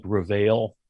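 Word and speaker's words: Réveil: It looks like Reveil, but it's French, so I believe you don't pronounce the L.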